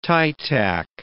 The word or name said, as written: tie tac